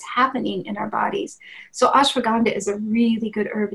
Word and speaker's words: ashwagandha: Oh, yes, ashwagandha is spelled A-S-H-W-A-G-A-N-D-H-A, ashwagandha.